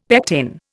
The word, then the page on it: pectin: Indian